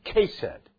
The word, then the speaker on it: hesed: It's spelled as H-E-S-E-D and pronounced Kesed.